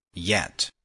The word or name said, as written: yet